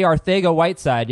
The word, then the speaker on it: Arcega-Whiteside: Odell Beckham, Amari Cooper, Kenyon Drake, J.J. Arcega-Whiteside, yes, that is how you pronounce it, Andrew Luck, Jimmy Garoppolo threw 5 straight passes to the defense yesterday.